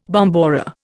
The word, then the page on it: bombora: Indian